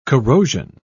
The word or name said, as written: corrosion